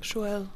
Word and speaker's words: Joelle: Joelle. Joelle. Ein bisschen Französisch, aber. – Joelle. Aber nicht zu viel Französisch.